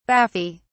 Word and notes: baffy: British English

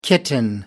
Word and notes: kitten: American English

